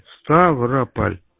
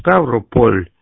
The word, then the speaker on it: Ставрополь: Ставрополь. Ну, пишется Ставрополь, а говорят на Ставрополь. Ну, как в Москве, Москва, так у нас Ставрополь.